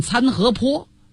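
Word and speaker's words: 参合陂: 参河碑就是现在的内蒙古凉城东北，这碑字是多音字，也有人管它念参河坡。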